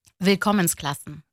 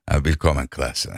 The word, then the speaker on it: Willkommensklassen: Tam telaffuzunu söyleyeyim bana. – Willkommensklassen. – Willkommensklassen, evet. Benimki olmamış, evet.